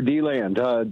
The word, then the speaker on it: DeLand: DeLand, Florida. Am I saying that right? – Yes, sir, DeLand.